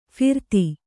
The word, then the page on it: ಫರದೆ: pharade